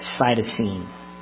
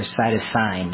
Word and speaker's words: cytosine: One of them is called cytosine, or cytosine, depending on how you say it. C-Y-T-O-S-I-N-E.